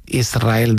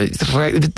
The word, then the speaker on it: Israel: Y es difícil, Y de diminutivo, de chiquitito, Israel, el que ella quería, porque Israel creo que le sonaba muy grande, ¿no? Israel. ¿No? – Y la S con la R es difícil de pronunciar.